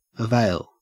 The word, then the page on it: avail: Australian English